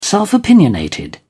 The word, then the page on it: self-opinionated: British English